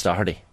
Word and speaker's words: Doherty: There's one way of doing it right, and it's Doherty.